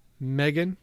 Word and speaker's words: Megan: The goat farmer that I think that Luke did the story with, and he can't pronounce Megan, and it drives me nuts. Megan, like magazine, it makes me fucking crazy.